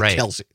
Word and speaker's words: Kelce: It looks like Kelce, but he pronounces it Kelce.